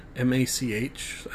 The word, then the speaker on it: Mach: Out of Phaze is about the early adventures of Mac M A C H, as in, uh, no, how would you pronounce that again? I've just been reading it in my brain, Mac uh, Mach, yeah, as in like Mach Speed, M A C H, and Bane.